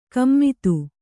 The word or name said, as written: ಗುಮ್ಮತಿ